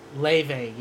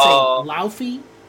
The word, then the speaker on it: Laufey: Leve, yeah. It's spelled Laufey. It's so people, it's. I used to say Laufey.